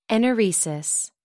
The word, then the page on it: enuresis: American English